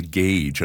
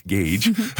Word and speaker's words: GAGE: is what we call the GAGE. And I think you mentioned it originally. It's the Geospatial Agroecosystem Inference Generator, and it's not pronounced GAG, GAGE.